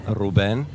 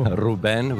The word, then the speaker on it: Ruben: My name is Ruben. Actually, we pronounce it in Armenian, Ruben. – Ruben, cool. – Ruben, with a kind of emphasis on the E.